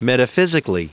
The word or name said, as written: metaphysically